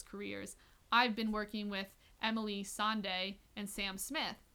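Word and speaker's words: Emeli: Is that how you pronounce it? – I think it's Emily.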